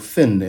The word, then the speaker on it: Fionn: He does this with the ancient Irish story of Fionn, or Finn in the modern pronunciation.